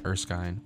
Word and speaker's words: Erskine: And the night before they do the treatment, so the doctor, I'm just gonna call him that going forward, so I have to try and say Erskine. Well, said it right that time, whatever.